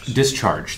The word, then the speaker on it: discharge: Number two, it decharges very quickly. So if you have a big spike, and forgive me if I'm not saying this right. – Discharge, thank you.